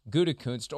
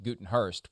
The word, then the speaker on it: Gutekunst: He reported, among other things, that in group text messages with teammates, Aaron Rodgers has mocked general manager Brian Gutekunst, or as Brett Favre called him on Favre's podcast this week, Gutenhurst, which is butchering it even more than you ever have.